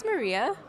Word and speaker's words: Maria: My name's Maria.